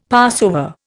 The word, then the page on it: Passover: Indian